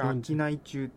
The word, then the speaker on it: 春夏冬中: これ秋内中って読むんですよ。